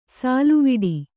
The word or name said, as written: ಸಲ್ಲವಿಡು